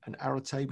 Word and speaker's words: Arete: And Arete, which is spelled A-R-E-T-E, but pronounced Arete,